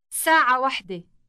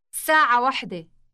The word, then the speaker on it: ساعة واحدة: But remember that the word for the number one is different from the one used for telling time. How do you say 1 o'clock? – ساعة واحدة. ساعة واحدة.